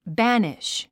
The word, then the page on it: banish: bǽniʃ; バァニッシュ